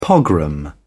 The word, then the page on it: pogrom: British English